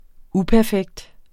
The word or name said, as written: uperfekt